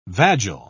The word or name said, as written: vagile